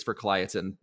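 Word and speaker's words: Verkligheten: I had to do the album review on it, so I had to keep looking it up on how to pronounce this. I'm pretty sure, if I'm remembering correctly, it's Verkliatin, but yeah.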